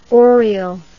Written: orial

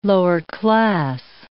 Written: lower-class

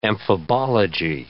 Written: amphibology

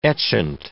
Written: etchant

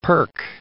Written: perk

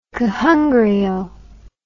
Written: kohanga reo

